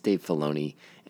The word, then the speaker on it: Filoni: It is not Dave Fiolini. It's Dave Filoni.